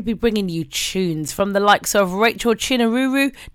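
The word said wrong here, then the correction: Chinouriri: No, I said that wrong. Chinuriri. There you go.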